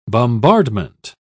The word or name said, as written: bombardment